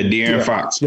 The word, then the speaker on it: De'Aaron Fox: The agency represents De'Aaron Fox of the, that's just correct, I said it right. – Yeah, De'Aaron Fox.